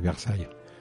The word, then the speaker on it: Versailles: ¿Cómo se pronuncia Versalles en francés? – Pues es muy parecido. Se dice Versailles.